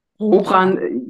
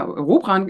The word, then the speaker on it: Robran: Robran, der Vor- und der Nachname wird meistens nicht so, kommt meistens nicht so, wie der geplant war von meinen Eltern, ausgesprochen. Aber ja, Robran ist, kommt aus dem Norden und heißt eigentlich die Räuber.